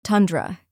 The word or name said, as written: tundra